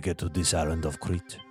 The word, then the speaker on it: Crete: It's pronounced Crete, and it's an island in Greece. He's saying it wrong.